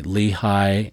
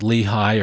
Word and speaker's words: Lehi: Whenever we're in the Arabian Peninsula and talking about Lehi or Nephi, we always use their pronunciation of Lehi and Nephi. That is because Semitic names usually put the emphasis on the last syllable.